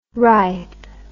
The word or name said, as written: rithe